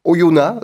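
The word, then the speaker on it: Oyonnax: Alors vendredi, Oyona, dont on rappelle qu'on ne prononce pas le X, si mes sources sont bonnes,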